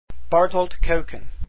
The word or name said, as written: kuijken